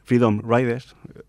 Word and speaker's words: Freedom Riders: Su primera campaña en el 61 fue la de los llamados Viajes de la Libertad, Freedom Riders, lo he pronunciado bien. – Muy bien.